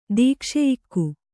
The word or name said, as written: ದೀಕ್ಷೆಯಿಕ್ಕು